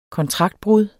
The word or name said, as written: kontraktbrud